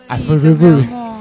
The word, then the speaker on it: Azurduy: Azurduy. Me da lástima cuando... – Bien, bien pronunciada la Z, muy bien. Pues nadie le da bola a la Z.